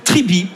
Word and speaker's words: Tribee: Et aujourd'hui, Zoom sur la plateforme Tribi. Ça s'écrit T-R-I-B-E-E.